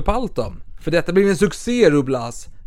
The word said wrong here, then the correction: Ruy Blas: Ruiblas, förlåt, förlåt. Jag vill ha det spanska franska uttalet. Ruiblas.